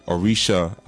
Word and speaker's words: Orisa: Like, you know, Orisha, a lot of times we'll see it as O-R-I-S-H-A. But it's actually O-R-I-S-A. The S is a sh. So even with Shango, it's really S-A-N-G-O, not S-H-A-N-G-O.